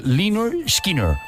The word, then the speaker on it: Lynyrd Skynyrd: Oye, a propósito de pronunciación en inglés, que sepas a partir de ahora que es siempre Lynyrd Skynyrd. – Es que, Lynyrd Skynyrd, ¿no? – Sí, vale.